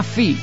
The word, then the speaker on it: Phi: El grupo se llama Phi, P-H-I, pronunciado en inglés.